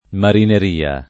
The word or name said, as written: marineria